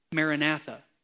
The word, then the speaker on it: Maranatha: In the original spoken language, Aramaic, it's pronounced Maranatha.